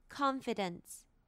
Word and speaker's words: confidence: 那现在 confidence 的 con 是重音，所以我会把它念成 a 的音 confidence，而不是像 concise 一样它发的是轻音，所以重音会影响到一个音的发音 confidence。